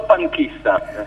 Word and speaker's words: No Panchista: Dimmelo tu come si pronuncia. – Si pronunciano panchista, è abbastanza facile, si può pronunciare in tutte le lingue.